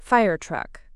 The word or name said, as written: firetruck